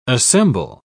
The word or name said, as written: assemble